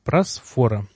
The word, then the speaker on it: просфора: Что такое просфора? Ударение делаю здесь просфора, чтобы было понятно, потому что как только не делал, ты просфора и просфора. Вот специально спрашивал у греков, как правильно.